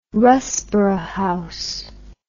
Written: Russborough House

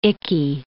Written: icky

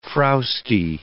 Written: frowsty

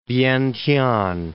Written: Vientiane

